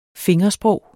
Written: fingersprog

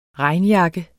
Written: regnjakke

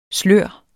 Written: slør

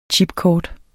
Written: chipkort

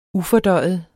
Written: ufordøjet

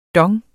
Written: dong

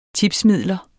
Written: tipsmidler

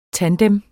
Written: tandem

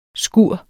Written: skure

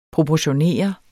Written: proportionere

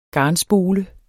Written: garnspole

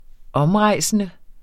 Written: omrejsende

